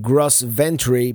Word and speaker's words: Gros Ventre: I stayed at the Grosvent campground, which is it's written Gros Ventre, but it's pronounced Grosvent.